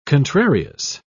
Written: contrarious

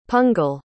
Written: pungle

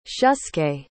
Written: shusuke

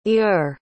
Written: eure

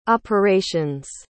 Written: operations

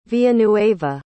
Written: villanueva

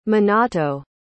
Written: minato

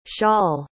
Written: shaul

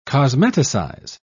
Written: cosmeticize

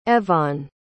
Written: Evonne

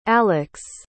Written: alix

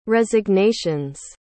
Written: resignations

